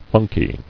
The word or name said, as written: Funky